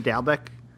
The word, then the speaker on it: Dalbec: Aaron Dalbec? – That's perfect.